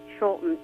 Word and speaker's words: Scholten: Vince Scholten, S-C-H-O-L-T-E-N.